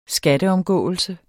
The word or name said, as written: skatteomgåelse